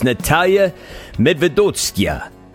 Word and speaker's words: Nataliya Medvedovskaya: It's Nataliya Medvedovskaya.